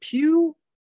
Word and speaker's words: Pugh: Is it Pugh? – Yes. – That's how you pronounce it.